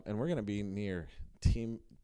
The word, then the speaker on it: Timișoara: Oh, Timișoara is what, yes.